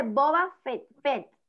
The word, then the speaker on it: Boba Fett: y la quinta, el libro de Boba Fett, creo que se pronuncia así.